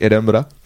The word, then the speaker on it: Edinburgh: So I tried to say it correctly, like Edinburgh. – Well, that's the sort of Scottish way of saying That's the Scottish way, yeah.